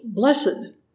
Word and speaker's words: blessed: In the blesses at the beginning of Matthew 5, it's the other word for blessing, and it's pronounced in two syllables, blessed.